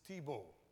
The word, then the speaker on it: thlibo: The word tribulation in Hebrew is tibo. The tribulation, the meaning, the word, original word in Greek is tibo. L is silent, T-H-L-I-B-O.